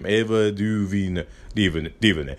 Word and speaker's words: DuVernay: I believe that's how you say her name. Ava DuVernay.